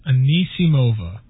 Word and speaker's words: Anisimova: Anisimova.